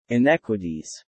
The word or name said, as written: inequities